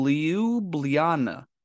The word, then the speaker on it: Ljubljana: Because I look at it and I see Jublana. Ljubljana. – Ljubljana. – Ljubljana? – Yes, Ljubljana. So I thought the L was silent. Apparently in Slovenia, they say, no. You pronounce all of it. You must. – Except for the J's are E.